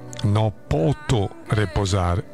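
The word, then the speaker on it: No potho reposare: ho continuato a leggere no foto riposare, invece ovviamente è no poto riposare, cioè l'h va dopo la t, non dopo la p.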